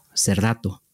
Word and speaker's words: Serrato: Serrato. – Okay. I got to get that hard T in there.